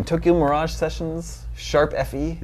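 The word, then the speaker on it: Tokyo Mirage Sessions ♯FE: R-I-M-E is a real word. – June 24th is a Wii U game. Tokyo Mirage Sessions, Sharp FE. – Yeah. – Is that how you say that? – You've said it correctly, yeah.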